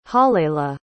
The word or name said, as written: halleli